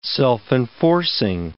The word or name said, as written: self-enforcing